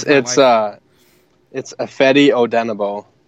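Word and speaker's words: Ifeadi Odenigbo: Ifadi Ojinabo, I can't pronounce his last name. – It's Ifedi Odenabo.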